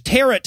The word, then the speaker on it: tarot: "Witches use tarot cards." Tarot, which rhymes with carrot.